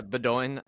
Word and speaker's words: Bowdoin: I went to Bowdoin. I might be saying that wrong. But yeah, Bowdoin.